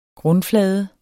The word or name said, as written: grundflade